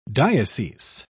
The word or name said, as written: diocese